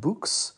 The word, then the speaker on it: Boox: called Books, and that is spelled B-O-O-X.